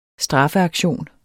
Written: straffeaktion

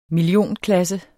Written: millionklasse

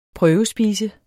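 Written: prøvespiser

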